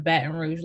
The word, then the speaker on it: Baton Rouge: Baton Rouge, Louisiana. How they say it? Baton. – Baton Rouge.